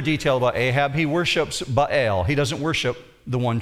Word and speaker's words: Baal: Baal. When you see his name, B-A-A-L, in the Bible, you pronounce it that way, Baal.